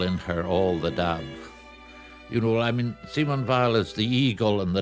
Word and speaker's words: Simone Weil: He prefers the pronunciation Simone Weil in the 1950s.